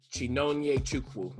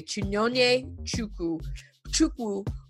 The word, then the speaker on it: Chinonye Chukwu: Do you know how to say it better than I would? – I would say Chinonye Chukwu. – That's exactly what I was gonna say. Chinonye Chukwu, who won the top prize at Sundance for her directing.